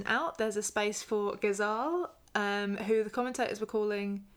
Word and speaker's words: Ghezzal: It was Ghezzal. They were calling him Ghezzal. Ghezzal the Ghezzal.